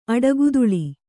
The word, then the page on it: ಅಡಿಗೂದಲು: aḍigūdalu